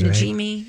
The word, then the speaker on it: Najimy: I see Kathy Najimy. Is that how you say her name? – That sounds Najimy.